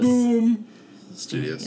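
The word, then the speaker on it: Boom! Studios: So this week I read the book God Shaper from Boom Studios. – Boom Studios. – Yes, you have to say it like there's an exclamation point yeah.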